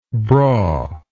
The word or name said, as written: brawlys